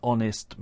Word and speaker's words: honest: Other interesting examples would be the phrase an honest man. Now, honest begins with H, a consonant, but we don't pronounce it. We start with the vowel sound o, so we say an honest man.